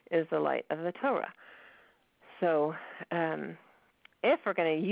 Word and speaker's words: Torah: pronounced clearly so that you can hear the or in it. One of the things I do for new Balei Tshuva is write down Torah for them, T-O-E-R-A-H, so they learn how to pronounce it like a not Balei Tshuva. It shouldn't be Torah, Torah.